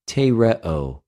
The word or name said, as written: τηρεω